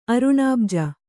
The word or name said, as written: ಅರುಣಾಬ್ಜ